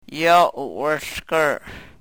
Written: yo u-wrhlkr

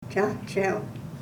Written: chahchew

